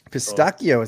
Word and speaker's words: pistachio: Pistachio, is that how like the fancy people say it? – It's how they pronounce it in Italy.